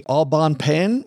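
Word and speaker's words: Au Bon Pain: Also another company, Aubon Pen, which I probably slaughtered the pronunciation of that, but I think we've all seen. – Sure, you nailed it.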